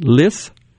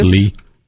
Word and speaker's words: Lis: Okay, so this got shared with some Democrats, such as, I don't know how to say it, L-I-S. How would you say that? Liss? – Liss? Lee. – It's Lee. Okay. The S being silent, the I being short in this case.